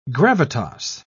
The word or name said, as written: gravitas